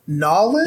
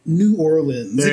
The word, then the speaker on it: New Orleans: Is it Nolens? – There you go. – Or New Orleans? It depends on who you're speaking to.